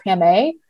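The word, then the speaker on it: Pamay: And so a nickname, a pet name that my mom called me, sounded something like Pamé.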